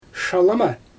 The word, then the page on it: ʃalɯma: Xyzyl